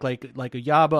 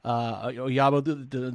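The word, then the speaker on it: Ojabo: If not, do you like Oyabo? I hope I'm saying it right. It's a soft J there. Oyabo, the Michigan kid, like which DNs do you think at 13 might smell right for Cleveland there? – I would love to see Trevon Walker there.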